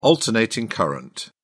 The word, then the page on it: alternating current: British English